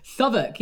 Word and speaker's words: Southwark: Southwark? – Southwark, yeah.